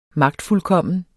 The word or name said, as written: magtfuldkommen